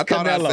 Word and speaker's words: Canelo: You said carne asada. You said Canelo. – Then he said Carmelo. – He's Hispanic. – Canelo. No, it's Canelo. – I said it right.